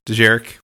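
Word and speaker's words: Dejarik: But Holo Chess is also another name for Djeric. Did I say that right? I think so. – Yeah, Djeric, which is what we saw in A New Hope.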